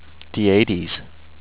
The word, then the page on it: Dietes: dee-ATE-ees